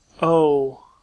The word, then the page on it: o: American English